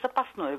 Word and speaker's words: запасной: Запасный, запасной, то и другое до сих пор можно. Старшая норма – это запасный. Запасной – это более, скажем так, новое. Это связано с конкуренцией ударения в прилагательных, которые, в свою очередь, связаны с различием книжного, церковно-славянского и разговорного древнерусского языка.